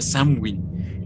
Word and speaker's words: Samhain: Yo, bueno, yo escuché a un este a un irlandés, lo busqué en YouTube cómo lo pronunciaba y lo pronuncia Samwin.